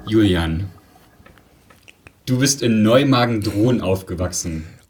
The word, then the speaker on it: Neumagen-Dhron: Leute sagen, also das Neumagen sagen Leute meistens richtig, aber Dhron wird manchmal mit T oder das H an einer anderen Stelle ausgesprochen, dann ist es so Neumagen. Drohon oder sowas, das ist ganz lustig, genau.